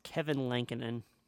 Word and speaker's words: Lankinen: It's Kevin Lankinen. – Kevin Lankinen, a little Finnish freak, a little naughty Nordic boy, 8-3-3 with a 9-2-1 save percentage.